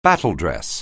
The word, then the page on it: battledress: anglais